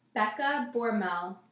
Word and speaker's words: Becca Bormel: Yes, my handle is at Becca Bormel, and that's spelled BECCABORMEL.